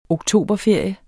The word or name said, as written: oktoberferie